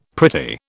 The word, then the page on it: pretty: British English